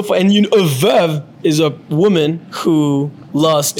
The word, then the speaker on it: Veuve: Veuve Coquelicot. – Veuve Coquelicot? – that's just not the same. – So the correct way to pronounce it is veuve. So veuve. And then Clicquot.